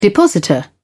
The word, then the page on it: depositor: British English